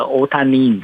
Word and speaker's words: 大谷: 我愿意当大谷翔平的老婆，然后他们叫做那个，因为那个大谷翔平的日文发音叫那个大谷叫欧那个欧塔尼，你知道吗？